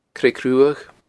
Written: Creag Ruadh

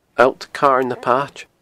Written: Allt Carn na Paite

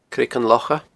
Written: Creagan Locha

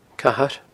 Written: Cathair

